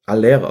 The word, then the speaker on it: Allaire: Diese Nummer mit, ähm, was hatten wir, Dominik Heinz gegen Sebastian, ich glaube, der spricht sich Aller aus oder so ähnlich. Nicht, nicht Haller wie der Helmut, Ach, sondern ja, Aller.